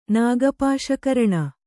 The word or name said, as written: ನಾಗಪಾಶಕರಣ